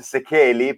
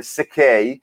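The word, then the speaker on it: Székely: poi non è nemmeno la pronuncia giusta, ma a un certo punto mi sono dovuto arrendere al fatto che noi poi lo pronunciamo così. Questo, la pronuncia giusta sarebbe Sekei, ed è un nome che arriva da Dracula praticamente, infatti io qui sono una specie di vampiro.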